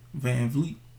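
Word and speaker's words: VanVleet: Van Vliet.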